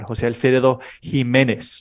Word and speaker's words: Jose Alfredo Jimenez: Jose Alfredo Jimenez.